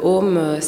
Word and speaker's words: AUM: Donc le Om, ça se prononce, ça s'écrit A-U-M. Il y a trois syllabes là, donc le A, le U, le M.